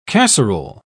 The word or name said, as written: casserole